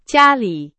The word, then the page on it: 家里: Chinese